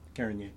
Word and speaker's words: Carignan: Carignan.